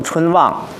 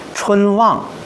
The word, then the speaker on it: 春望: 比如说我们读春望，我们不能把春和望都读成重的，春望不合适，应该是中重格式，春用中等的力量，望用比较大的力度，而且望又是一个仄声，应该是什么呀？春望，你看这就不一样了。